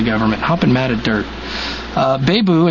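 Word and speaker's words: Babeu: So Pinal County, Arizona Sheriff Paul Bebu is hopping mad at the federal government.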